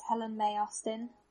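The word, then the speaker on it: Helen Mae Austin: Yes, so the name I use for acting is Helen May Austin. May is spelled M-A-E, and I'm that name on all the social medias that I should be findable on, for example, Instagram.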